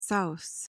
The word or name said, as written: souse